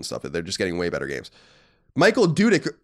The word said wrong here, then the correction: Dudich: or no, he actually, this guy actually wrote it and said I was saying his name wrong. I'm trying to remember. Let's say Michael Dudich.